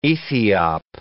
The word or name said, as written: Ethiop